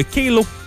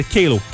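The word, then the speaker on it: Qalo: Il va vous falloir peut-être une Kalo. C'est donc un jonc de mariage, Kalo, Q-A-L-O, Q-A-L-O, c'est prononcé Kalo, Kalo, pas Kalo, Kalo.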